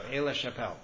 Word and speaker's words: Aix-la-Chapelle: Aix-la-Chapelle.